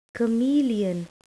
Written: chameleon

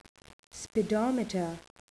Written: speedometer

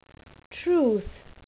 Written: truth

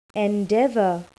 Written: endeavour

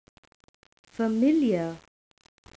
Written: familar

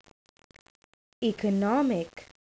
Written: economic

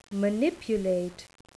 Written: manipulate

